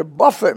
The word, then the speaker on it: buffet: to buffet me, lest I should be exalted above measure. Now, Baptists buffet themselves, but not in the same way Paul did. Buffet, spelled the same, pronounced differently.